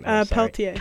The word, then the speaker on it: Peltier: I already forgot how to say his last name. – Peltier. – Peltier, thank you.